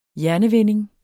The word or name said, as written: hjernevinding